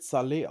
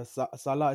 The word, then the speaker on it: Saleh: Ich, ich glaube, er wird Saleh ausgesprochen äh, Salah ist der Fußballer.